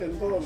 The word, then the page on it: kengkong: Ansus